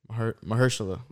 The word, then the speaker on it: Mahershala: I don't know how to pronounce his first name. – Yeah, Mashallah Ali. – Is he playing Blade? – Mahershala. – Mahershala Ali. I never know how to pronounce his name.